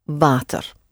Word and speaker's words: water: Water. So the W is pronounced like a V and is a real long open ah sound with a slight touch of the R at the end. Water.